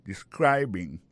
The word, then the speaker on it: describing: Ça nous carré là, ça nous constate là, nous ca constater que il y a prononcé mot de describing accent tombé sous deuxième syllabe là.